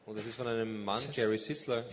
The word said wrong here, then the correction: Sittser: Sitzer. – Jerry Sitzer hat das Buch geschrieben. – And it's spelled S-I-T-T-S-E-R. – S-I-T-T-S-E-R.